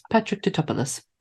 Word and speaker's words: Tatopoulos: Speaking to Patrick Tatopoulos, but they did, and I'm sure that's how you say his name. So if it isn't, it is Patrick Tatopoulos.